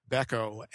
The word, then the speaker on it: Beco: It's spelled B-E-C-O, Beko, and it is actually very comfortable.